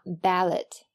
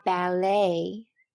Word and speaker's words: ballet: 千万不要一激动就读成了 ballet，其实它的读音应该是 ballet，重音在后边，并且最后的 t 是不发音的。